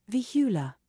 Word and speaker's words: vihuela: Vihuela.